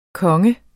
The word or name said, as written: konge